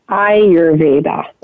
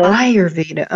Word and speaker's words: Ayurveda: Ayurvedica. Did I pronounce that right? Ayurveda? – No, it's pronounced Ayurveda. – Uh-huh. Ayurveda, okay.